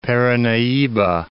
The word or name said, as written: Paranahiba